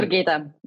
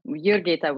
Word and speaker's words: Jurgita: Jurgita. Jurgita with a silent J.